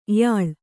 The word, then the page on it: ಯಾಳ: yāḷa